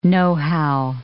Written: know-how

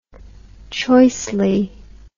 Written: choicely

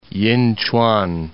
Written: Yinchuan

